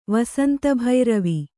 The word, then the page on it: ವಸಂತಭೈರವಿ: Kannada